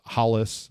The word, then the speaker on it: Rolls: So sometimes it's pronounced Hollis. That's the way a lot of folks say it in Brazil. Sometimes it's Halls. It's spelled Rolls, but this is who we're talking about.